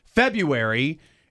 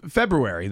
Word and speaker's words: February: Yeah, I mean, look, it seems like in the vernacular, a lot of people say February instead of February, the proper pronunciation.